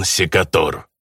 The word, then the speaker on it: Секатор: «Здесь он, Ивон Секатор». – Прозвище орк произнес во французском манере с ударением на последнем слоге.